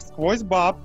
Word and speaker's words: SQWOZ BAB: Все потому, что вот этот набор непонятных букв S, Q, W, O, Z, B, A, B читается как сквозь баб.